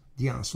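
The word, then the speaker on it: Dyens: His last name is spelled D as in Douglas, Y-E-N-S, Dianz, Roland Dianz.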